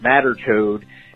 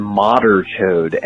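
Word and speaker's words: madarchod: Apparently, banchode, matterchode is actually behenchode. B-E-H-E-N-chode, and modderchode, M-A-D-A-R.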